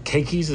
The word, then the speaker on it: keikis: You know, I remember getting a tour here of the New Hope Kids ministry and seeing all the beautiful little, is it keikis? Is that how you say it? Yeah. Little keikis, little kids.